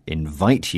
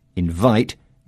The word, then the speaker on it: invite: Yes, invite here is a noun with the stress on the first syllable. But if I say to Catherine, I want to invite you to my party on Saturday, invite is a verb with the stress falling on the second syllable.